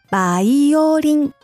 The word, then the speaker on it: バイオリン: 私たちは B の発音もしませんよ。バイオリンと言います。